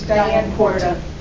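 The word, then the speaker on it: Diane Kwarta: My name is Diane Quarta, and it's spelled K-W-A-R-T-A.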